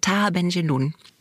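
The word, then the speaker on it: Tahar Ben Jelloun: Und das erste Buch, das du vorstellen wolltest, ist Sohn ihres Vaters von Taha Benjeloun.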